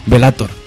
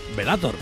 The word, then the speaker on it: Bellator: Es que, a todo esto, explícamelo un poco, porque es que no sé cómo, cuál es la pronunciación. ¿Vilator? ¿Villator? ¿O cómo va el rollo? – Bellator. – Bellator, sí. Bellator, o sea, Bellator de Bellator.